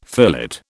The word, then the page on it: fillet: British English